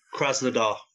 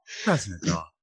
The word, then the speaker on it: Krasnodar: Yeah, I can't pronounce that one. – Krasnodar. – There we go. – Oh, someone's been on Google Translate. Krasnodar.